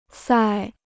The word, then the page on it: 塞: sài